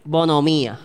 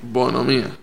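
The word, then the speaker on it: bonhomía: No sé ni cómo se dice. Bonomía. Bonomía. Bonomía. Es con, H, es con H entre medio de la N y la O. Bonomía.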